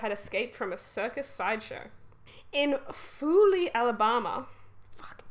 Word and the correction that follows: Foley: Alabama. – Foley, come on. – I keep saying it wrong.